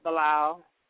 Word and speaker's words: Bilal: So my first name is Saniyyah, last name is Bilal.